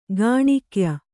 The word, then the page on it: ಗೋನಿಕಾಯ: Kannada